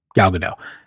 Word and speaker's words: Gal Gadot: It's just easier for me not to pronounce that T at the end. So Gal Gadot.